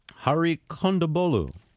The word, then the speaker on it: Hari Kondabolu: Hari Kondabolu. Did I say it right? – Yeah, you're one of the few that says it right.